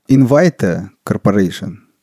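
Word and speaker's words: Invitae Corporation: Invita Corporation. Правильно произношу? – Да. – Ух ты. – Может быть, Invita, я не знаю, как они ее произносят.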